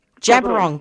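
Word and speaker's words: Djab Wurrung: and follow Jabiron JABWURRUNG. – Jabiron, that's how you pronounce it.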